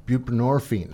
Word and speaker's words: buprenorphine: Buprenorphine? Am I saying that right? – Buprenorphine? – Buprenorphine.